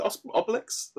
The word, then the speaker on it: Obelix: Is it Obelix? I think it's always that thing of you when you're a kid reading the book, you kind of make up your own pronunciation, and no one actually. – it's Obelix.